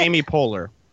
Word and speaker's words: Poehler: Does anybody know how to say it? – Amy Poehler. – Thank you.